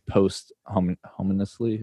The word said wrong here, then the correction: posthumously: Is that how you say it? – Posthumously? – Yeah.